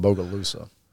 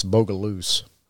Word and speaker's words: Bogalusa: Now, the people that are from that area, they don't pronounce it Bogalusa. It's Bogaloose.